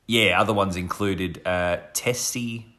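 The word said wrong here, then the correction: tsetse: How do you say that? – Tetsy. – Tetsy. Tetsy right.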